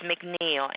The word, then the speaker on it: McNeil: A-M-Y is my first name. Last name is McNeil. M-C-N-E-I-L.